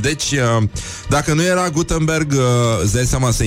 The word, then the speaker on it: Gutenberg: Uh, nu în ultimul rând, uh, Johannes Gutenberg uh, este inventatorul, probabil vă aduceți aminte, uh, și nu se pronunță Gutenberg, uh, cum a sugerat pe vremuri uh, Academia Casavencu. Uh, Este Gutenberg și nu Gutenberg, da?